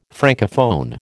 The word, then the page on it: Francophone: American English